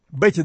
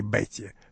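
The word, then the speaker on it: Bette: Бетти Дэвис была мятежницей с первых своих лет. Она даже имя свое стала писать на французский манер, так что, строго говоря, его надо произносить «Бет», а не «Бетти», что иногда вне Америки и делалось, кажется, именно в послевоенном Советском Союзе.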